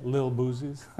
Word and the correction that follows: Lil Boosie: How you say it? Lil Boosie. Lil Boosie. There's no T in Lil.